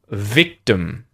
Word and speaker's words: victim: The first syllable, victim, victim. We fell victim to the scam. So as a result, im at the end of victim becomes a schwa vowel sound and you have victim, victim, victim.